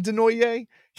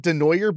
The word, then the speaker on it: DeNoyer: I taunt him by calling him DeNoye. He pronounces it DeNoyer, but it's totally French, so you're not really wrong.